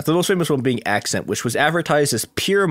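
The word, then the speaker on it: Ac'cent: Okay, there's like, it's spelled it's Accent, but it's spelled A-C apostrophe C-E-N-T.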